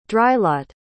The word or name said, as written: drylot